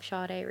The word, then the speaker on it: Sade: I've been listening to a lot of Sade recently.